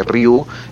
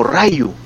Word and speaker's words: Ryu: Y el doblaje en inglés, lo, por la fonética en la que está escrito que Ryu es R-Y-U, los eh, norteamericanos lo pronuncian como Rayu.